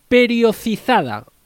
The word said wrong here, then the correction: periodizada: o priorizada perdón, nutrición periodizada, ¿Qué es y cómo trabajáis con esa nutrición periodizada?